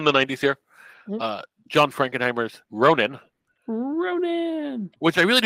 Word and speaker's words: Ronin: Ronin rhymes with bonin.